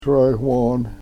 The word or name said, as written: srewon